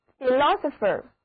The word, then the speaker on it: philosopher: 还有这个词根的两个单词非常有意思，第一个是 philosopher， philosopher 拼写是 P-H-I-L-O-S-O-P-H-E-R，字母 I 的读音是短的 E，而第一个字母 O 读作 O，后面一个字母 O 和结尾的字母组合 ER 都读作 E，那字母组合 PH 的读音都是读作，整个单词重音第二个音节，好复杂，一起来念一遍吧。